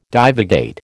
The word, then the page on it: divagate: American English